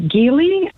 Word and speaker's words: Geely: You know, you're one of the few people that pronounces Geely correctly. I checked. It is a hard G, not a soft G, but most people say Geely.